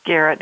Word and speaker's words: Garrett: Garrett, yeah.